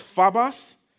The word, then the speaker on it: phobos: It's pronounced phobos, right? It has the same roots as the English word phobia.